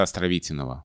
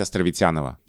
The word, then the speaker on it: Островитянова: А Флоренция Леонидовна мне говорила, мы с ней общались на эту тему, что это сознательное решение, потому что в беглой живой речи когда произносишь Островитинова, Островитинова, Островитиного, Вот это очень сложно, остается много звуков заударных, и удобнее произносить Островитянова.